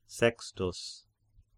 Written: Sextus

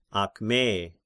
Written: Akme

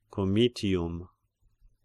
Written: Comitium

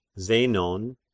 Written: Zenon

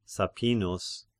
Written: Sappinus

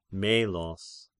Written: Melos

